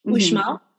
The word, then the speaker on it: Uxmal: So it's spelled Uxmal, Uxmal, but the X is like a sh, Uxmal.